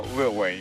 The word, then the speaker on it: Lil Wayne: Lil Wayne. – Okay. Oh, nice. Little Wayne. – No, Lil Wayne. You got to say it right, Jerem.